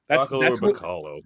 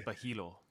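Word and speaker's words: Bachalo: Bacalo. – That's what you get with the print, with the written word. – It's Bajilo.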